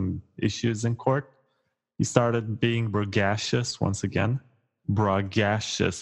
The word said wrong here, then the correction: braggadocious: How do you pronounce that well? – Braggadocious. – Braggadocious.